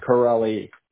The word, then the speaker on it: Correale: I just wanted to make sure I had that spelled correctly because it was kind of – how do I pronunciate that anyways? Correll? – How do you think? – Correll? – Yes. – Correll? – Correlli. – Correlli. Oh, the E's. Okay, gotcha.